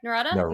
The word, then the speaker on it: Nerada: Did I say that right? Nerada. – Like Nevada.